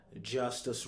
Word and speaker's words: Justus: As always, you can find me on Twitter and Instagram at Justus Rogers TV, and that's spelled just the way it sounds.